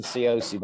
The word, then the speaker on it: Cioci: It's Jason Cioci, by the way. – Cioci. – Cioci. – I butchered the name from the very beginning.